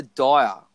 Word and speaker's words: Dyer: Father Dyer, I think I pronounced it right. – Yep.